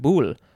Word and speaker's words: boule: This is a Greek word, boule, literally means will, W-I-L-L, from boulestai, which means to wish. And now we have the second form, but it is pronounced a little bit differently. It looks like it's just boule, one syllable instead of the two syllables for the last one.